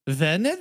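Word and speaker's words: Wernher: The W's are V's. – Yeah, go ahead. Do it. I don't give a fuck. – Werner von Braun or Werner Magnus Maximilian Freiherr von Braun.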